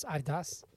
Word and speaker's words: Adidas: You know that Americans in the West have said that wrong for decades. The actual name of the company is Adidas. – Adidas. – Adidas. – Adidas. – It's Adidas. It's supposed to be pronounced Adidas.